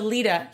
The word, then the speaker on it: Lyta: I keep changing her pronunciation. – Lyta. – Okay. So Lyta, I feel like he loves her.